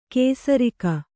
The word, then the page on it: ಕೇಸರಿಕ: kēsarika